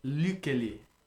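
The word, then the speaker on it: Lykke Li: and now for some more of Lucchelli. We believe that is the better pronunciation. He's got some pretty famous songs with, he's spelled L-Y-K-K-E space Lee, however you say that.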